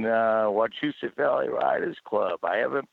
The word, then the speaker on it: Wachusett: Wachusett. Go with me, because I know I want to train you how to say this. – Wachusett.